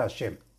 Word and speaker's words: Hashem: Y se deberá meditar el salto nombre Hei Shin Mem, que se pronuncia Hashem.